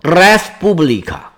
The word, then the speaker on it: respublica: 罗马呢，它是借用了希腊的这个词儿，拉丁语里面呢读作 respublica，它的原意是公共资源的意思。